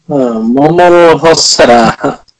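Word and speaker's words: Mamoru Hosoda: Mamoru Hosada.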